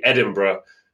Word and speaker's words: Edinburgh: And Edinburgh was actually my inspiration, because obviously you don't say Edinburgh the way you write Edinburgh.